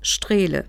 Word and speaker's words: Strehle: Mein Name ist Frau Jutta Strehle. Das schreibt man S-T-R-E-H-L-E.